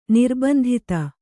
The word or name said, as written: ನಿರ್ಬಂಧಿತ